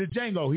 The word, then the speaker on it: Django: That was like Django say the D is silent.